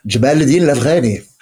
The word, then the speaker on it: Jamal al-Din al-Afghani: Jamal al-Din al-Afghani.